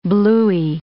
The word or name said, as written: bluey